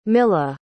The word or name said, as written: milah